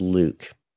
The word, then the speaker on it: LIWC: Which I pronounce Luke.